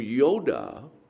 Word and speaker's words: Yod: But why did he choose the name Yoda for the master of the Force? What does the name of God begin with? The first letter of the name of God is Yod. That's how you pronounce it.